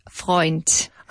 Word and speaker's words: Freund: No, actually, it's German, and it's pronounced Freund.